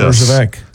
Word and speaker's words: Herjavec: Herjavec? – Yes. – Herjavec.